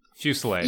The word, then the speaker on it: fusillade: Fusillade? – Fusillade.